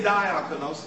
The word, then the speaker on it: diakonos: Now, remember I said diakonos. I didn't say diakonos. It's pronounced diakonos. So that is an E sound to us English people. So let's change the I to an E.